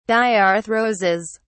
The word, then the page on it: diarthroses: British English